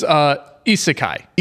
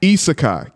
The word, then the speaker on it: Isekai: How do you pronounce it? Is it Isekai? Asiki? Asikai? – It's... – Suck my balls. – Yes. They're wrong. – There you go. – It is Isekai. – Isekai. Gotcha.